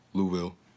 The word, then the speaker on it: Louisville: It's not Louisville. Louisville. – Louisville. – No, Louisville. – Okay. – Louisville. That's how they say it over there.